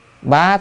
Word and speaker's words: bá: Bá thiên vạn sắc, bá là trăm, hay còn đọc là bách,